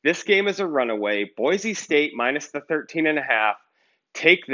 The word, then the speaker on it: Boise: The locals say Boise. Boise. You don't say the Z. You say Boise.